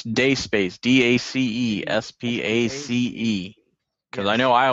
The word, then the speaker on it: Dacespace: Guys, just so you know, it's not pronounced Daces Space. It's a rookie mistake. It's Day Space, okay? – Thanks, PNG.